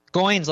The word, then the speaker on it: Goins: Goins like coins.